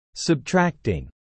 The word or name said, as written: Subtracting